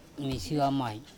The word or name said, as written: mishua mai